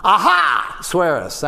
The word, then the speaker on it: Ahasuerus: kind of aha swearus that's how i think they pronounced it back then